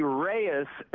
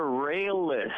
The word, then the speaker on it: Reyes: In Espanol, two L's is pronounced as a Y. – Yeah. – We pronounce Llano as Llano. That's why Reyes would be Reyes instead of Rayless.